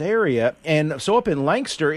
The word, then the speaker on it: Lancaster: Well, you know, you can you can phonetically spell it L-A-N-K-S-T-R, Lancaster. – Lancaster. – Yeah, put a little apostrophe in there between the K and the S, Lancaster.